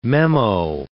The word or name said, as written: memo